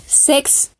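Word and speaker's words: sechs: Ejemplo, la, palabra que se escribe se escribe S-E-C-H-S, e, pero se va a pronunciar sex y significa seis.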